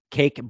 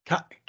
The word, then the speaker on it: Kaik: Cake Brito? – Kaik. – I'd say his first name. It's not Cake. Kaik. It's not spelled like cake, but.